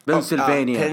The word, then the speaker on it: بنسلفانيا: انطقها زين بنسلفانيا.